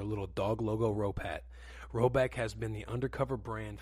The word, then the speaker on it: Roback: Now shout out the boys at Roback, which is pronounced Roback, like Brogan Roback.